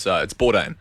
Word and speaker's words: Bourdain: Is it Bourdain? Anthony Bourdain? Is that how you say his name? – No, oh, I'd say Bourdain. You reckon it Bourdain? – Yeah, it's Bourdain.